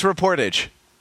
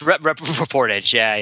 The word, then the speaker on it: reportage: It's reportage, reportage? Is that how you say it? – Or? Yeah, sure. That's not how I say it, apparently, but I think you were right. That is the correct way to say it.